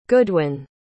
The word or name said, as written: goodwin